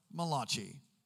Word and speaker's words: Malachi: If you're Italian, it could be pronounced Malachi.